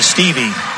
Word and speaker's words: Stueve: I did confirm it's Ava Stevie. Spells her name S-T-U-E-V-E, and I wasn't sure how to pronounce it there.